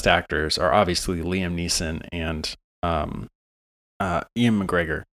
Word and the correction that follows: Ewan McGregor: Ewan McGregor. I always say that wrong.